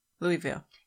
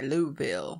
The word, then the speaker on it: Louisville: Louisville. – Yeah, but they don't say it like that. – They say Louisville.